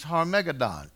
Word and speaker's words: Harmagedon: The correct pronunciation is Hebrew. It's Harmagedon from the Valley of Megiddo.